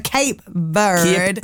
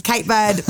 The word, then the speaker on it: Cape Verde: Thank you for joining me this morning and giving the proper pronunciation. So Cape Verde. – Cape Verde.